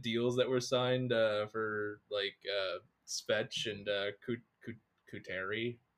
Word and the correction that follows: Couturier: Kuteri? Kuteri? Sean Couturier? – There you go.